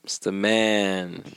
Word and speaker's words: Stamann: Stam. Stam. Stamann. He's the man.